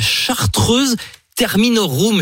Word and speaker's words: Chartreuse Terminorum: Il s'agit de la Chartreuse Terminorum, je prononce bien Oui. Bravo, bravo.